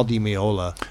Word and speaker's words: Di Meola: From one of my favorite artists, a great guitarist, Al Di Meola. – Is that how you pronounce it? – Okay. Yes. And he put out a lot of great records. – Di Meola, okay.